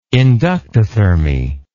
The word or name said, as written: inductothermy